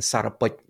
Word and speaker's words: Sarapot: And Sarapot is the Khmer pronunciation of Sarvagnya, or omniscient.